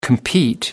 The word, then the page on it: compete: American English